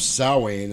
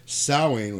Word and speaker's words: Samhain: He goes, but the last real observance of Samhain, and thank God he pronounced it correctly, Samhain was 3,000 years ago, and the hills ran red with the blood of animals and children.